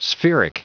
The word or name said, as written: spheric